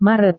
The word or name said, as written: maret